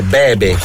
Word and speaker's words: Bebe: Bibi Rexha, David Guetta, Sad Girls. – E non è Bibi Rexha, Giovanni, è Bebe.